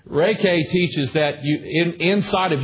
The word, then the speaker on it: Reiki: It's spelled R-E-I-K-I, but it's pronounced Reiki.